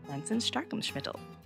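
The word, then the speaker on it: Pflanzenstärkungsmittel: The correct pronunciation is Plansenstarkungsmittel.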